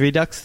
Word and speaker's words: Redux: So one of your stories, Redux, is Redux the right word? Redux of the Living Dead? Am I pronouncing that right? – That's how I pronounce it.